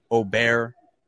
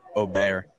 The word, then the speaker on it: Åberg: Ludvig Åberg is the correct pronunciation of Ludvig. – Luda. – Åberg.